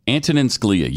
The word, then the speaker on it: Antonin Scalia: Yesterday, Antonine Scalia. Kim Kardashian. – You're talking about a Supreme Court justice he can't pronounce. Really? Antonin Scalia.